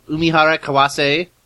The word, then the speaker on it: Umihara Kawase: Yes, Farrell Umihara Kawase, am I saying that right? – Yeah, pretty much.